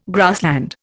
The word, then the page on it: grassland: Indian